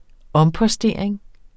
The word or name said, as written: ompostering